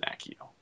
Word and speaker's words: Accio: Which I always pronounce as Accio until the movie, and they said Accio.